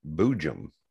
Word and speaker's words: boojum: How, how do you yeah. spell that? – B O O J U M. – Boojum. – Okay. Yes. I hope I'm saying it correctly. I think I am but yeah, I don't know how else you could say it. – Boogum. – Yeah, that's pretty. it's like a soft J.